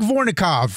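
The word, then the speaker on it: Vorkunov: I think I destroyed his name. – Wait a minute. What is his name? – How you say his name? – I know. How do you say that? – Mike Vornikov, friend of the show.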